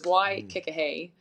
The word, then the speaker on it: Waikikahei: So it's kind of like a play on that. So it's Waikikahei, but it's spelled H-E-I.